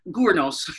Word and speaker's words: Gurnos: So that's really interesting, because I did not know that you had, is it Gurnot? Is that how you say his name? – Gurnot. It's a Welsh name, Gurnos.